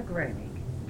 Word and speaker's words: Gramig: Gloria Gramig, G-L-O-R-I-A-G-R-A-M-I-G. – Thank you. Where do you live? – I live at 7807 Sunbury, S-U-N-B-U-R-Y, Lane, L-A-N-E.